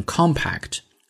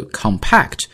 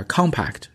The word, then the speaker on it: compact: 但是呢如果我们讲说是啊，它是一个组合套装做名词，它就应该读成 compact。 So compact 是它的形容词，而 compact 是它的名词，注意一下这个重音的变化。